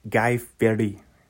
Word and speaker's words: Guy Fieri: He pronounced it correctly. He's like, no, it's Guy Fieri.